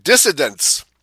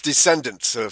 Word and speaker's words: descendants: They say that when pronounces, it sounds like dissidents, you know, D-I-S-S-I-D-E-N. We are dissidents from the mainstream, but we're talking about descendants, okay, so... from your Swedish pronunciation, it tends to sound like dissidence to a lot of people.